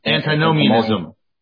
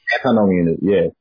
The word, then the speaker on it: antinomianism: Antinomianism. – Antinomianism, yeah.